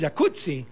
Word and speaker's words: jacuzzi: Entonces deberíamos pronunciar el jacuzzi. – Exacto. – Como pizza. De... como rampazzo, sí. Como Claudia Rampazzo.